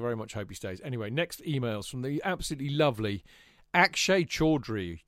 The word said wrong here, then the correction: Chaudhry: or Chaudhry, I suppose, would be a better pronunciation, who, of course, we've known for a long time.